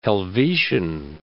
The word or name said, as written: Helvetian